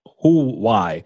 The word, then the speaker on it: Huawei: Next story here comes to us from our friends over at AV Magazine, Hawaii. Hawaii, Huawei.